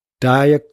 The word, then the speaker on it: dire: The next one, number two, dire consequences. dire consequences. We don't really pronounce the R, or we don't pronounce the R, I should say.